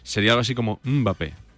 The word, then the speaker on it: Mbappé: Es como que haces el sonidito y luego ya dices Mbappé, pero no es muda.